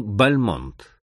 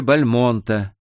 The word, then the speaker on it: Бальмонт: Ударение в своей фамилии все Бальмонты делали на первом слоге, но герой этого очерка изменил семейную традицию и перенес ударение на второй.